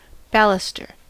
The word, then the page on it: baluster: American English; /ˈbæl.ʌ.str/